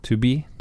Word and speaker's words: 2B: Jeg tror det er 2B, altså 2B. 2B, to be, to be continued.